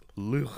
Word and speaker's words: Luc: It's pronounced Luke.